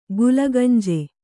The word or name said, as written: ಗುಲಗಂಜಿ